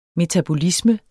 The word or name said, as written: metabolisme